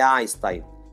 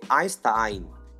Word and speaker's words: Einstein: Eu acho que a pronúncia no alemão lembra muito a caririense. Não é Einstein, é Einstein.